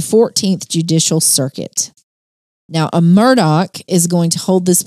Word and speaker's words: Murdaugh: Well, first of all, if you notice, it's spelled M-U-R-D-A-U-G-H. And you're like, which is confusing. – You're saying that wrong, but it is pronounced Murdaugh, despite the spelling.